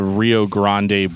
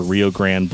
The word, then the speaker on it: Rio Grande: So moving on to Rio Grande Blood, or Rio Grande Blood, depending on how you want to pronounce it.